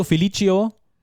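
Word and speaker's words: Felício: Spricht man Felicio, Felicio? – Ich denke, Felicio ist die Aussprache.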